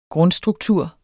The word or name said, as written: grundstruktur